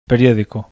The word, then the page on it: periodico: acento lexical; bizkaia